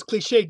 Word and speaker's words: cliché: Because cliché used to have an accent on the end. – Ah, that's right, of course, like resume. – And I mean, clique doesn't, but cliché no. – used to have an accent on the E, which you know you're supposed to pronounce like with you know, the A sound. Like peak, P-I-Q-U-E, there's that fabric.